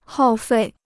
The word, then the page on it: 耗费: hào fèi